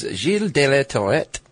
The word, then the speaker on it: Gilles de la Tourette: Yes, his name was Gilles de la Tourette. It's how I'm going to pronounce it in my faux French.